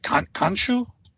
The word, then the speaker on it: Khonshu: Khonshu? – Yeah.